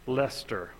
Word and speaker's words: Leicester: She received a BA in humanities from Brigham University and an MA in Victorian studies from the University of, how do you pronounce that to be sure? – Leicester. – Okay, I would have pronounced it wrong.